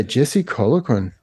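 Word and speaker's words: Jesse Colquhoun: So, yeah, Jesse Colloquin. Okay. – Nice pronunciation, though. Nice. Well done.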